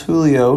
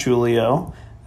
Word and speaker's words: Julio: Pronounce Julio, not Julio.